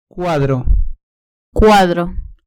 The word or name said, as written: cuadro